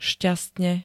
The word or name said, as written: šťastne